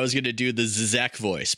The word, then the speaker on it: Žižek: Zizek, I should say. So you didn't even say it right.